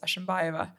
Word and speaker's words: Ashimbaeva: But my second name is Ashimbaeva, which is, if you just read it, it's as it's pronounced as it's spelled.